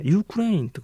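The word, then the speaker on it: Ukraine: で、ウクライナですけど、英語ではですね、ユークレインとかユークレインっていった発音なんですね。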